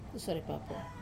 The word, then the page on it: sore papo: Wamesa